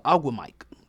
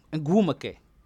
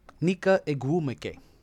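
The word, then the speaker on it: Ogwumike: I forgot the last year I was calling her Aguamike. Ngumake. Nika Ngumake. Thank you, Jalen and Jacoby, for helping me pronounce that name.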